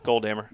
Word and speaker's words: Goldhammer: Yeah, it's Goldhammer. – Goldhammer. – It's not Goldhammer. Goldhammer. – No.